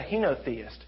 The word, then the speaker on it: henotheist: He's a Henoist. A Henoist. Is that how you say it? Henoist. What am I trying to? Not a monotheist. He's not a polytheist. He's a henotheist.